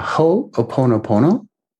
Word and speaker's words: Ho'oponopono: Ho'oponopono. I'll have to spell it for you. Yeah, it's H-O apostrophe O-P-O-N-O P-O-N-O.